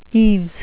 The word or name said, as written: eaves